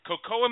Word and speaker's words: Kokoa: Cocoa Magazine. That's spelled K-O-K-O-A. Actually, I knew the correct pronunciation.